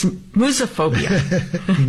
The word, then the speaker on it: musophobia: It's musophobia. – Moose? Spell that. – I think it's musophobia. Musophobia. M-U-S-O-P-H-O-B-I-A. – Wow. – The word comes from M-U-S, moose. – Which is like mouse. – Which is mouse, right.